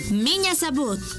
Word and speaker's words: меня зовут: M-I-Ñ-A. Меня зовут. Z-A-V. U-T. Sabut. Miña Sabut.